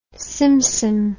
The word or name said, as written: simsim